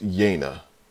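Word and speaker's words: Yeine: So her name is Yaina. I had to go to Audible just to, like, double check and see. That's okay. That's how you say it.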